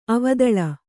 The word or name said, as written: ಅವದಳ